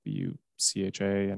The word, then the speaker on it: Bucha: Honestly, it's pretty simple. Bucha, and you know, sounds like it's written, but I was kind of like, where is this place?